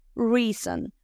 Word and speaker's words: reason: Se dice reason, reason. Aquí qué vamos a hacer para pronunciarlo bien. Número uno, R suavecita, re, re, o re, ni ri, re.